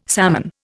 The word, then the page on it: salmon: Indian